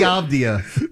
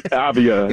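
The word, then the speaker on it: Avdija: Denny Avdia. – That's his name, yeah. Avdia.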